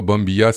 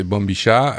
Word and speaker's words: bombilla: Oui, ben alors, bon, alors ça va être le bon moment, un peu comme la Bombicha, euh, et c'est pas Bombilla, c'est Bombicha, euh, de nous reprendre et de faire un message euh, vocal pour nous, reprendre sur, ça.